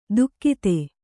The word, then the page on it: ದುಕ್ಕಿತೆ: dukkite